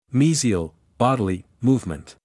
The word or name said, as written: mesial (bodily) movement